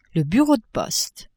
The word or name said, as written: bureau de poste